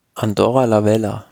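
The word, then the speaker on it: Andorra la Vella: Etappe Nummer 14 ist dann die Etappe von Serré nach Andorra-la-Velle. Weil, wie spricht man das aus? – Andorra-la-Velle. – La-Velle, okay.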